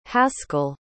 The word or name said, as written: haskell